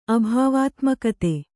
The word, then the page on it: ಅಭಾವಾತ್ಮಕತೆ: abhāvātmakate